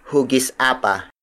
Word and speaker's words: hugis apa: Tagilog is spelled as T-A-G-I-L-O-G. A modern variation is hugis apa. Hugis apa. Hugis apa. Hugis apa is spelled as H-U-G-I-S hyphen A-P-A.